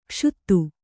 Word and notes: ಕ್ಷುತ: kannada; kṣuta

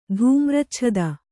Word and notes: ಧೂಮ್ರಚ್ಛದ: dhūmracchada

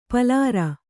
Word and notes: ಪಲಾರ: palāra

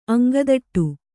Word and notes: ಅಂಗದಟ್ಟು: aŋgadaṭṭu

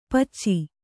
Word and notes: ಪೆಚ್ಚ: Kannada

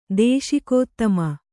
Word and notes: ದೇಶಿಕೋತ್ತಮ: Kannada

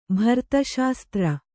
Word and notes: ಭರತಶಾಸ್ತ್ರ: kannada

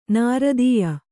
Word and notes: ನೃತ್ಯ: Kannada

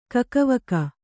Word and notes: ಕಕ್ಕಾವಿಕ್ಕಿ: kakkāvikki

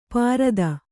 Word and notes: ಪಾರದ: pārada

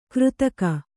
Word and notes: ಕೃತಕ: křtaka

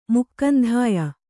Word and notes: ಮುಕ್ಕಂಧಾಯ: mukkandhāya

